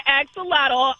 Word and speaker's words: axolotl: There's four syllables, so axolotl.